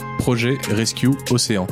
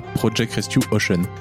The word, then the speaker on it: Projet Rescue Océan: Dans le prochain épisode, on sera avec Benoît Schumann, le pompier biterrois à l'origine de la géniale association Projet Rescue Océan. Et ça se prononce comme ça, pas Project Rescue Ocean.